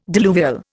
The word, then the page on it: diluvial: Indian